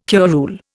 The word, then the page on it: curule: Indian